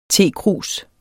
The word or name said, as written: tekrus